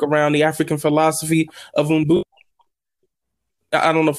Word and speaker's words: Ubuntu: Yes, yes. Ubuntu. I think you said it right.